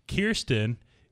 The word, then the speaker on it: Kirsten: And it's funny because a lot of people say, like, you know, Kirsten or Kristen, but she says, Kirsten, it rhymes with deerskin.